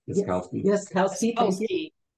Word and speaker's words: Yaskowski: In addition, we have Stanley, and I never say his name right. – Yaskowski. – Yes, I always put too many letters in there.